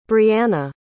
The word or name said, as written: briannah